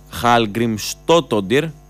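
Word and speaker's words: Hallgrímsdóttir: η Ισλανδή, Χαλγκριμστότοντιρ, έτσι προφέρεται, Χαλμικστότοντιρ,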